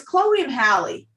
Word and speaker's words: Chloe x Halle: It's Chloe and Halle. – Okay, but there's an X, so I'm never sure how to pronounce it. – I think they just did that for the style.